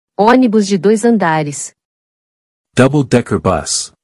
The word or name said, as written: Onibus de dois andares